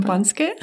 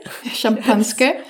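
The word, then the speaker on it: Champanske (шампанское): The only thing is that you have this generic term called, please pronounce it, I can't. – Champanske. – Champanske. – Which is a Russian translation of champagne.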